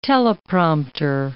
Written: teleprompter

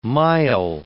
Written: mile

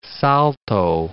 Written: Salto

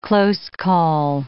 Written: close call

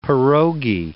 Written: pierogi

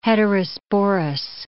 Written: heterosporous

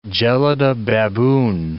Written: gelada baboon

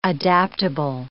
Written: adaptable

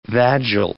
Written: vagile

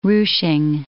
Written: ruching

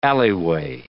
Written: alleyway

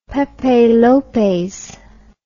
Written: pepe lopez

